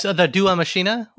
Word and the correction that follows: deus ex machina: whatever, maybe? The thing at the last minute that saves you? – Deus Ex Machina? – Yeah, I just totally butchered that.